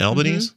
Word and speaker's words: Albanese: Am I saying that right? Albanese? Mm-hmm.